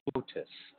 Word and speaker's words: flautist: We have Herb Wilborn Jr., and he is a flutist, which is extremely unique. Oh, no, flautist. Flautist, I meant to say. I'd never say that right. I meant to say flautist.